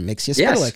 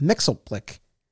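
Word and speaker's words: Mxyzptlk: And in that episode, famously, in that Superman the Animated Series episode, he goes through this whole bit explaining the pronunciation, Mixia Spellick. Yes. Mm-hmm. And so that's always how I've said it and how I've heard it said in subsequent iterations. But on this show, they go with Mixelplick, which I've heard other comic book fans say, and I've always, I'm so sorry, bear with me here, but I've always kind of looked at that as like, I don't know, the poor man's pronunciation is like, you don't figure out how to say.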